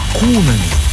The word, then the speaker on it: куна: А когда славянские народы познакомились с западноевропейскими и арабскими серебряными монетами, то назвали их кунами в честь пушистого зверька. В словаре Дали есть два слова «куна» с ударением на первый слог.